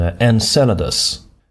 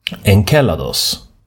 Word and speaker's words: Enceladus: På engelska så säger man Enceladus med ett S-ljud. Men det grekiska ordet, när månen är namngiven efter en av giganterna i den grekiska mytologin. Och där är jag ganska säker på att ordet ska uttalas Enceladus. Med K, det skrivs med kappa.